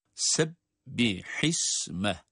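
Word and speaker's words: سبح اسم: Bu ayetlerin okunuşlarıyla alakalı detaya baktığımız zaman Sebbihisme. Bakınız se, sin harfi, ince okunan bir harf. Sebbihisme.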